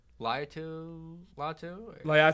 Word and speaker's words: Laiatu Latu: How do you say it? Laiatu Latu. – Laiatu Latu. That's okay.